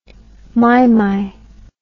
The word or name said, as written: maimai